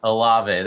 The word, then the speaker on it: Olave: Chris Olave, I think is his name. – Olave, there it is.